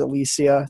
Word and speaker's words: Alicea: And my last name's Alicea.